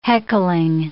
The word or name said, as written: heckling